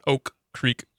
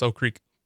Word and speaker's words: Oak Creek: Yeah, I went to Oak Creek High School, and you don't really pronounce that first K. It's not Oak Creek High School, it's Oak Creek.